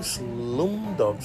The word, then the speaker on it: Slumdog: Slumdog, sí.